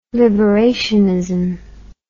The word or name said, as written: liberationism